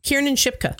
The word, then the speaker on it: Kiernan Shipka: Kiernan Shipka. – Kiernan Shipka.